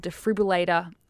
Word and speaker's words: defibrillator: They spent over 30 minutes administering CPR and even multiple defibrillator. Did I say that right? I never say that word right. Defibrillator? – It sounded fantastic.